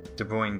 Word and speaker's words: De Bruijn: They're called De Bruijn sequences. – De Bruijn? – Brown is spelled... Yeah. – B-R-U-I-J-N. – Yes, that's it. – That's what they're called, eh? De Bruijn sequences.